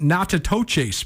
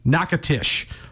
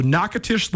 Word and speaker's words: Natchitoches: Northwestern State is in what when you see it, it looks like it's also pronounced Natchitoches, but it's not. It's pronounced Nacatish. So Nacatish, Louisiana is where Northwestern State hails from.